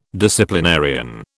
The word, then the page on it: disciplinarian: British English